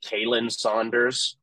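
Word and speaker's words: Khalen Saunders: But even though I think what Blaine said, that he pronounced it Kalen Saunders and not Colin.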